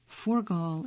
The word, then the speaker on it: Furgal: Forgal is spelled F-U-R-G-A-L.